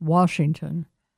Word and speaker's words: Washington: There is no R in the word. It is pronounced Washington.